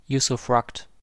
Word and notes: usufruct: American English

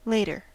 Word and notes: later: American English

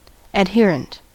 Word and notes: adherent: American English

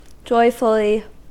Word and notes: joyfully: American English

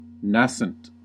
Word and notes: nascent: American English

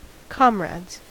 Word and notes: comrades: American English

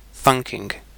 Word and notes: thunking: British English

